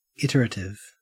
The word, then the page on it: iterative: Australian English